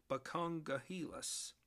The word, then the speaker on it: Buckongahelas: Buckongahelas.